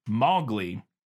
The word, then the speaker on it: Mowgli: According to her, Mowgli is pronounced Mowgli. Rhyming with cow, so Mowgli, almost, not Mowgli.